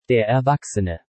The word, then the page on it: Der Erwachsene: Deutsch